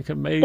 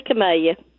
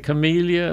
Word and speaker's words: camellia: I say camellia. – And, you know, some people say camellia, and I think, not so great. It's camellia. Yeah.